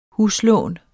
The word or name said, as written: huslån